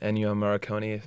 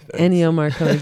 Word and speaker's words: Ennio Morricone: Ennio Morricone. – Ennio Morricone.